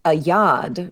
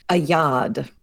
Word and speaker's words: oeillade: This one is pronounced a yod, but it's spelled really monstrously. O-E-I-L-L-A-D-E. Thank you, French. A yod.